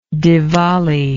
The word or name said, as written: divali